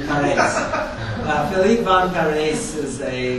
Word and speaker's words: Parijs: Philippe von Paris, he's Flemish, from Belgium. His last name, P-A-R-I-J-S, is pronounced Paris, not Paris, or, and it's the I-J is just a Flemish construction.